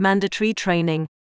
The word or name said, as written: mandatory training